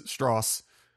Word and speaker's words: Strauss: Um, and you really just see Oppenheimer, uh, and doubly so for Robert Downey Jr. as Strauss it's it's pronounced Strauss. – It's the Southern way. – like, yeah, yeah, yeah. Did I little, did I pronounce it yeah, wrong or right? I, I, was like, I'm just I, commenting I, on. I know it's not Strauss, which is how it's kind of spelled.